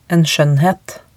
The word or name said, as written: en skjoennhet